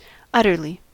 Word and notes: utterly: American English

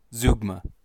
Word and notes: zeugma: American English

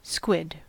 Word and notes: squid: American English

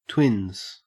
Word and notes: twins: Australian English

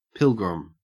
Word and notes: pilgrim: Australian English

